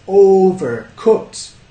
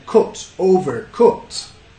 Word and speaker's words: overcooked: Overcooked. ¿Cuántas sílabas? Overcooked. Ya, yeah, tres. Overcooked. Overcooked. Cooked. Cooked. El estrés cae en cooked. Overcooked. Acá quizás en cooked, cooked, no cooked, but como book, libro, books, yeah, cooked, overcooked.